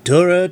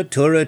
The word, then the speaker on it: Tora: it's pronounced Torah, Torah, Torah.